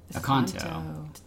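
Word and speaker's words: Accanto: Acento? – Aconto. – Said it wrong.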